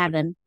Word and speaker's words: Lavin: Lavin. – Is it Lavin? Lavin. From the South, I can't pronounce anything properly. So pronounce it properly. – Lavin.